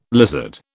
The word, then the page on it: lizard: British English